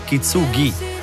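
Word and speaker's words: kintsugi: Vedi, vai avanti mesi a dire un titolo, Kitsugi, Kitsugi, Kitsugi, poi scopri dal testo che è Kitsugi. Kitsugi la pronuncia esatta.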